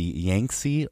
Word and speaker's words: Yangtze: But what I'm talking about, and my disaster, is called the flooding of the Yangtze River in China. And yes, I did pronounce that right. I Googled it.